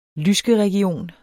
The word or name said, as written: lyskeregion